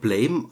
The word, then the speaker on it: Blame: Der wird zwar Blame geschrieben, also wie das englische Wort Blame, aber das wird Blam, also wie der Sound, den die Waffe des Hauptcharakters abgibt. Ne? Blam wird es ausgesprochen. Das ist die Master Edition.